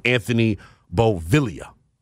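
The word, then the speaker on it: Anthony Beauvillier: And quick pronunciation on the left winger that the Caps just acquired from the Penguins in exchange for a second-round pick. It is Anthony Bovilla.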